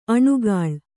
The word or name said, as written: ಅನಾಕುಲ